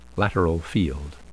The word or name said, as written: lateral field